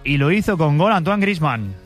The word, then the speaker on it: Griezmann: Antoine Griezmann, lo pronuncian bien, la verdad.